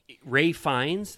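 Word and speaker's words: Ralph Fiennes: Ray Fiennes, that's how you say his name, right? – Yeah.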